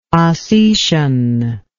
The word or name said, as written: Osetian